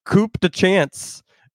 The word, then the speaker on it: Coup de Chance: It's pronounced Coup de Chance.